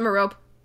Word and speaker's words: Merope: How do you pronounce it? – I pronounce it Merope.